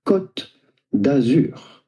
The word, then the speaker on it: Côte d'Azur: We are looking at how to pronounce Côte d'Azur. Côte d'Azur is how it would be said in French, but it'd be fine in English or American.